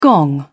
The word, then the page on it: gong: British English